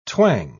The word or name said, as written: twang